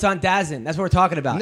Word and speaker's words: DAZN: And it's it's spelled DAZN, but it's pronounced DAZN. Like, is this is DAZN.